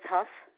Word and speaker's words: Hough: Judith Huff. – Can you spell that for me? – H-O-U-G-H.